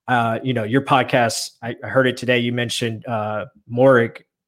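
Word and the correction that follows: Moehrig: Is that how you say it? – Merrick is how you say his name.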